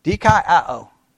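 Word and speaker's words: dikaioō: The Greek word, dikaiō. Right. Dikaiō. I'll spell it out for you. D-I-K. A-I-O-O. D-I-K-A-I-O-O. D-K-I-O is how it's pronounced. All right. Now, what is the meaning of D-K-I-O?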